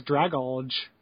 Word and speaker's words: Dragalge: So the two are Igglybuff and Dragalig. Nope, sorry. Dragalg. Dragalg? I don't know how to pronounce that. Anyway. – So I'll complete it as Dragalge.